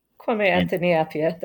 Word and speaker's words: Kwame Anthony Appiah: Kwame Anthony Appiah, that's right.